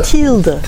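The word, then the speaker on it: Thilde: Tilda. It's pronounced like Tilda Swinton.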